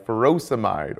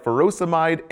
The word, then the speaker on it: furosemide: Potassium wasting ends in "-ide," like furosemide or hydrochlorothiazide. Both rhyme with dried.